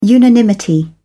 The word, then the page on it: unanimity: British English